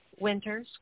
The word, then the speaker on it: Winters: One, if you you can see how my name is spelled, it's E-V-E-Y, Winters, with an S at the end.